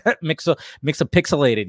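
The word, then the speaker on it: Mxyzptlk: I'm not even trying to Mixapixelated. Yes, I said it right.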